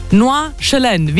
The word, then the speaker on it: Nordjylland: Nordjylland. – Wie als ob man Herdöpfel im Mund hat, hat sie gesagt, Siri, und SD nicht aussprechen.